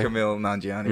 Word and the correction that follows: Kumail Nanjiani: How do you say his name? – I don't. – Oh, no. I personally do not know. – Oh, it's like Kumail Nanjiani. – Yeah. – Kumail. – Kumail.